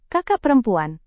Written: kakak perempuan

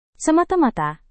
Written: semata-mata

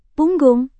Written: punggung

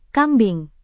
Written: kambing

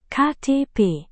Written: ktp

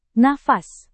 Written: nafas

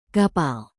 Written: kapal

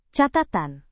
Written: catatan

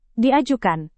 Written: diajukan

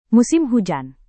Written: musim hujan

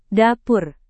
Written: dapur

